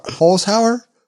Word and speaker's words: Holzhauer: Holzhauer? Holzhauer? – Yeah, Holzhauer. That's pretty good.